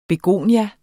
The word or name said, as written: begonie